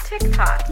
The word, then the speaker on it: TikTok: So let's learn how to pronounce TikTok. You close it with a K. Tik. Then we have talk.